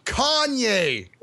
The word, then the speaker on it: Kanye: I saw Patrick Facebook about this earlier when he said Conway for president 2020. – You're mispronouncing it. – Oh, so Kanye. Yes. Kanye. Oh.